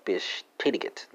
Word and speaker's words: bestätigt: Besetigt, spelled B-E-S-T-A-T-I-G-T, or bestätigt. Maybe that's a better pronunciation. Bestätigt, yeah. Spelling's right, though.